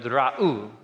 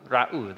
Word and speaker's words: ra'u: So you pronounce that word ra'u, ra'u, they see.